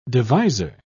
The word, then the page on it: deviser: British English